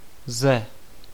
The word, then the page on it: from: American English; British English; /frɒm/; /frɑm/; /frəm/